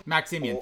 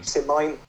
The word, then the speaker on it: Maximian: How old would Maximian, is it Maximian? Am I saying that correctly? – The co-emperor. – Maximian? – Maximian, thank you. – Maximine? – No, Maximian, thank you.